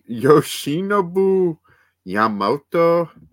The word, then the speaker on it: Yoshinobu Yamamoto: Yoshinobu Yamamoto.